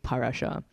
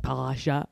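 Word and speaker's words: parasha (פרשה): Meryl was on the phone to her sister, Rachel, and Rachel was like, hey, why do you always say it like parasha? And I was like, because that's what the Nakud suggests that it is parasha. Mm-hmm. And then she messaged me later on. She goes, you're right.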